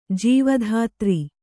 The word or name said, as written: ಜೀವಧಾತ್ರಿ